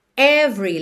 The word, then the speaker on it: every: Every, lembra que o E do meio não é pronunciado, então você já junta o V no R, every.